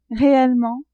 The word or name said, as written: réellement